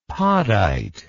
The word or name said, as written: podite